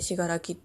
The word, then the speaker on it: 信楽: で、最初に言ってたしがらきっていう漢字なんですけど、どう書くかっていうと、信じるに楽しいって書いてしがらきって読むんですけど、そうなんです。